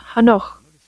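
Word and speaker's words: Hanoch (חנוך): The name Enoch in Hebrew is pronounced Hanoch and means a dedication to God,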